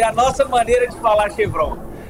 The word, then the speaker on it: Chevron: A pronúncia que se fala aqui no mercado é Chevron.